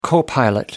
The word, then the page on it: co pilot: American English